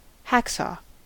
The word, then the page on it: hacksaw: American English